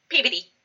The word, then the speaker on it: Peabody: Sorry, you were in puberty? – Peabody. – Peabody. – Peabody. – Could you spell that? – It's been a long time since I was in puberty. – Could you spell that? Because I can't. It still sounds like puberty. – P-E-A-B-O-D-Y. Peabody. – Oh, Peabody.